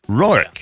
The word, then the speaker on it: Roark: Did I say it right? – Roark. – Roark. Yeah. Roark. – Just like me. I'm saying too much A in it. It's yes. Just Roark.